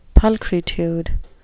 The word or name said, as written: pulchritude